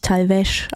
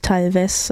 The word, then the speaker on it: Talvez: Natürlich Talvez ausgesprochen, nicht Talvez, wie ich es eben anmoderiert habe.